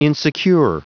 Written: insecure

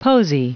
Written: posy

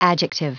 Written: adjective

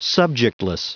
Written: subjectless

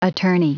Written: attorney

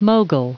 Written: mogul

